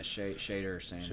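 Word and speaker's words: Shedeur Sanders: He and was, is it Shader Sanders? I don't know how to say his name. – Yeah, Shader Sanders.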